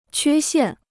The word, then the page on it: 缺陷: quē xiàn